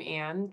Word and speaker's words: Ann: My Facebook's just my name, Ann Truesdell. And on Instagram. – Well, wait, spell that. – Yeah. Good point. A-N-N, and then Truesdell is T-R-U-E-S-D-E-L-L.